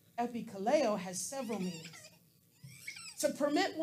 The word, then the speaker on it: epikaleo: The phrase there, will call on, is pronounced epikaleo. And it's spelled E-P-I-K-A-L-E-O.